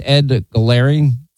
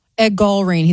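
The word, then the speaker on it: Gallrein: How do you say his name? It's Ed Galarian. – Ed Galarian.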